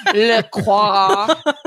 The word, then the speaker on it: LaCroix: It's a That's how they say it in France. – Le Croix. It's a LaCroix black raspberry, but it's like R-A-Z-Z.